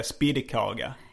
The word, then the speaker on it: spettkaka: jag tyckte väldigt mycket om det var spettkaka. – Ut, hur uttalade du det på den tiden? – Alltså eh. – den skånska uttalet är, har man hört är spiddekaga.